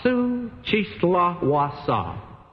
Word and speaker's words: Xochitlahuaca: And if you don't believe that that's the way to pronounce it, let me spell it for you. Xochitlahuaca. So you can see I pronounced it accurately.